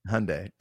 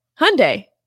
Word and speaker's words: Hyundai: Hyundai. I couldn't even say it right at first either. And they were like, think Sunday fun day. I was like, oh, cool. – Hyundai fun day. Hyundai.